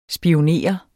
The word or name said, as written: spionere